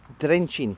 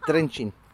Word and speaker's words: Trenčín: Trenčín, that's how you pronounce it. Trenčín.